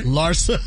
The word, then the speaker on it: Larsa: Larsa.